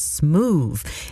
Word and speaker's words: Smoovee: And before that, Vel Lewis and Marion Meadows teamed up to bring us Smooth. And you have to say it like that, because it's spelled S-M-O-O-V-E-E.